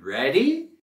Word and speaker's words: ready: Next, we have, are you ready? – Are you ready? – Are you ready? – ¿Estás listo? ¿O estás lista? Queremos subir la voz y entonación en la primera sílaba de ready.